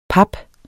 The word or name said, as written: pap